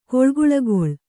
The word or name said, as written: ಗಾಳಿಗೂಳ್ಗೊಳ್